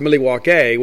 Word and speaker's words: Milwaukee: Because the word actually is pronounced Milwaukee, which is like an old Algonquin word.